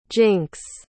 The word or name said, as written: jynx